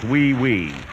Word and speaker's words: oui: Oui, oui, monsieur. – Wait a minute. That's pronounced wee wee.